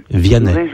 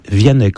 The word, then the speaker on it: Vianney: Vianney. Vianney, comme le chanteur. – Ah, Vianney. – Ou comme le Saint-Curé d'Ars.